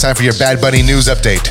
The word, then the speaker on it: Bad Bunny: Bad Bunny rhymes with money.